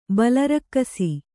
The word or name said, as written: ಬಲರಕ್ಕಸಿ